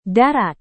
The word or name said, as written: darat